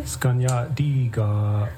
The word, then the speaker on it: skanyatdiga: Did I pronounce that correctly? – Skanyatdiga. – Skanyatdiga.